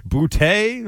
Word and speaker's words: Boutte: Boutte touchdown! – Boutte? Okay. Yeah. That's how it's pronounced.